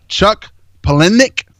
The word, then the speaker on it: Chuck Palahniuk: Chuck Palahniuk.